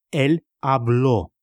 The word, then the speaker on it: habló: Él habló. Заметил, как меняются ударения и окончания. В испанском очень важно их не проглатывать.